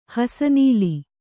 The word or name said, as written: ಹಾಸುನೂಲು